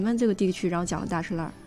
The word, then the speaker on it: 大栅栏: 其实到现在，大石栏为什么叫这个读音，其实没有官方的说法。 – 嗯，就是我们从小到大的时候，因为大栅栏它其实不符合那个老北京话里面的那个读音，所以有一种说法是它属于老北京的那个方言的一个变通。